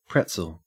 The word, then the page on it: pretzel: Australian English